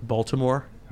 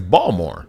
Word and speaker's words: Baltimore: I think it's so funny when, like, people come to Baltimore and they're like, Baltimore. They, like, pronounce the T. – Like, yeah. It's like, it's Baltimore.